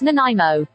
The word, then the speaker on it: Nanaimo: Scott, you're going to be playing for Martin in Nanaimo, BC, British Columbia, up there in the Canada. – It's pronounced Nanaimo. What the is a non-R-emo.